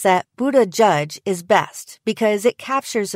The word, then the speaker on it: Buttigieg: On his Twitter, Buttigieg himself suggests that people should pronounce it like Bood-eh-jedge, though his husband offers the alternative Buddajudge.